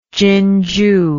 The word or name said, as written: Jinju